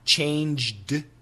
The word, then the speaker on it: changed: Now, what's interesting is when we say it in the past tense, I changed my mind. If we say it really fast, you don't hear the D in changed.